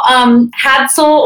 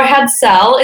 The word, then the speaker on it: Hadsell: No, too bad. No, Hadsell or Hadsell is great. So you were pretty close.